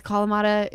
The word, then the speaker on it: Kalamata: Like, you give me a Greek salad with Kalamata. Is that how you say it? Kalamata olives? – Yeah.